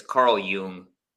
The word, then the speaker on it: Jung: It's Carl Jung.